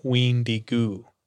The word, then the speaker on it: wendigo: It's pronounced Ween-Di-Go or Ween-Di-Gu in the Chippewa language.